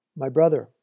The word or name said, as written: my brother